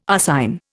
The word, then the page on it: arsine: Indian